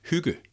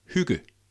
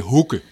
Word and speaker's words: hygge: And that's how you pronounce it, hygge. Try to say after me, hygge. – Hygge. – Yeah. Close. And I think you're much better than what I normally hear, because most people say hygge.